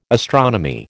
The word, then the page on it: astronomy: American English